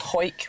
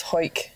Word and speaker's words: Hawick: But there's one called Hoik, which I think you're thinking of, which is spelled Hawick. – Yeah. – Yeah, it's Hoik.